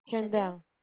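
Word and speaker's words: Shaindel: Yeah, so it's S-H-A-I-N-D-E-L. – All right. Is that pronounced Shandell? – Shandell. Yeah, you got it.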